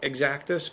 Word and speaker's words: Xactus: I always used to call it Xactus, but it's pronounced Xactus. according to Zactus.